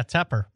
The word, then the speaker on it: Tepper: So What caused the trouble to start with, the tumult, was Temper? I always say this wrong. – Yeah, Tepper. – Tepper. Like, I always think Tupper wears a Tepper.